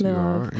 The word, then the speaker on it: Lwów: it says that when you were born in Lwów, am I saying that correctly? I hope. – Yes, Lwów. You are.